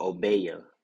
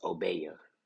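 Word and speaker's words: Obeah: It was called Obeya. So it's not Obey, it's Obeya.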